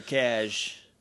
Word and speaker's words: Casje: Yeah, that picture for Cash. Which, if you don't know how to pronounce C-A-S-J-E, it's Cash.